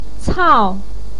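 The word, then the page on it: cao4: tone 4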